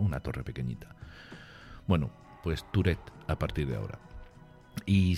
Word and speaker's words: Tourette: Y bueno, libro al que he querido volver para abordar un tema importante para Sachs como es el síndrome de, y lo voy a decir ya tal cual lo leemos en, español, en castellano, de Tourette, ¿vale? sin, darle esa pronunciación francesa. Tourette. Tourette sería en francés, que quiere, algo, que quiere decir algo así como pequeña torre.